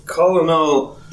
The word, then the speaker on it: Colonel: It's Colonel E.H. Taylor. – Colonel. – I can't even pronounce it. – I used to think it was Colonel. – Yeah. – And I'd be like, Colonel, is that just a different rank? – Yeah. But there's no R.